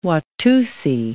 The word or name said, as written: Watusi